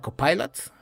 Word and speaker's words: Copilot: Copilot,